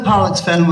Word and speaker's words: Pollexfen: We've got to pronounce that word very carefully. Pollex, P-O-L-L, in case you think it's something else. But Elizabeth Pollexfen was the daughter of a Sligo merchant.